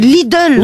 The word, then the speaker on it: Lidl: Il n'y a pas de O, c'est Lidl. – Oui, Lidl.